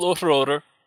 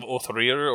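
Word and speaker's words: Óðrerir: So I mean, it's called Othror. I've seen some pronunciations of Othrir or Othrorir, but it more or less refers to the same mead that is found in the sagas and Eddas of Old Norse legend.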